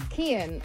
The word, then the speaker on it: Cian: I don't know how to say his name, though. Chan? – What? – Cian? – Kian. – Kian Ducrot.